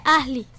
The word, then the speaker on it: Asley: No, es Asley. Se escribe Asley. Se pronuncia Asley.